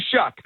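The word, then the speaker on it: Shough: All last year, I didn't pronounce his name right, and then I heard his announcers week one. I said, yeah. Tyler Shuck, that's how you do it.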